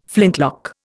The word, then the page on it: flintlock: Indian